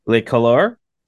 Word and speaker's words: Le Couleur: Today, we're with Steeven from Le Couleur.